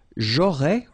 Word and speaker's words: j'aurais: J'aurais, remember, final S not pronounced.